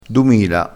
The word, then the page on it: dumila: dialettale